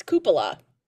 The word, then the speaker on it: cupola: I always called it the Cupola Bar. It's Cupola. – Cupola. – Cupola. So see, yeah. I was doing it all wrong.